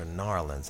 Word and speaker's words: New Orleans: New Orleans or Narlands? – Narlands. – I'm from here, man, so, you know, I don't want to be caught saying it wrong, bro.